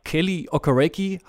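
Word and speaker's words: Kele Okereke: Kelly Okereke, habe ich es richtig ausgesprochen? – Ja. Na doch.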